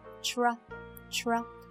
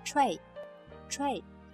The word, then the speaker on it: tray: Tra, tra. 我们一部分人也习惯读成 tray, tray. 这样比较简单粗暴，对吧？其实呢 tra, tra, 有一个舌头的滑动在里面。